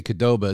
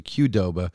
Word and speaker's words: Qdoba: But I'll tell you what we could do, because some fans are laughing at my mispronunciation, because I say Qdoba, it's supposed to be Qdoba. We could spell out K-A, like Qdoba.